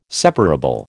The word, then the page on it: separable: American English